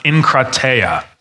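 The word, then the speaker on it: enkrateia (ἐγκράτεια): So when we're talking about self-control, we're talking about the word in the Greek that is pronounced enkrateia.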